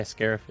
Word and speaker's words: Scarif: Yeah, Scarif.